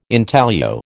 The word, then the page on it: intaglio: American English